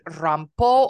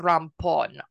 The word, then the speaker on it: Rampó: So he decides to appoint someone else, and this someone is a man named Rampó or Rampón, R-A-M-P-O, okay. – and it's got an accent on the O.